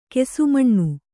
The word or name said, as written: ಕ್ಷಮಣ